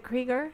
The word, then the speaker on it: Krieger: It's Krieger, correct? He pronounces it Krieger. – I believe it's Krieger.